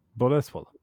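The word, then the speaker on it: Bolesław: Boleslaw. Är det så man uttalar det? – Boleslaw. – Boleswall? Ja. Ah.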